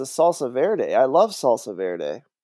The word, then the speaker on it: Verde: Is that how it's pronounced? Verde. – Verde. – I'm sorry. – It's Spanish for green. Come on.